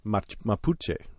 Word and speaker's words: Mapuche: Mapuche. Is that correct? – Right.